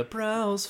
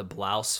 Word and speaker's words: Braus: Which is technically pronounced the Blouse family if you get into the real analytics mm-hmm. of the mm-hmm. story, which is just not very.